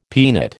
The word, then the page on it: peanut: American English